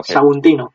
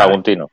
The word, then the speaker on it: Saguntino: No sé si me he equivocado porque lo he dicho bien, ¿no? – Sí, ¿vale? Saguntino. – Sí, bueno, Saguntino.